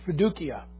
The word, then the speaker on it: fiducia: That's where faith becomes that third point, that fiducia. And fiducia. There's no soft C in Latin.